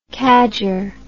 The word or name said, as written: cadger